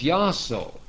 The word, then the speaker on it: Iaso: It goes back to a form pronounced as Yaso. Now the Greek letters are Iota, Alpha, Sigma, Omega. Iota, Alpha, Sigma, Omega. And they are equal to the I-A-S-O in our English letters with the long O sound.